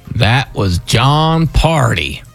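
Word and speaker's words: Pardi: Had a bunch of hearty answers. Rhymes with parties. It. But not party answers.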